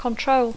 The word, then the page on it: control: English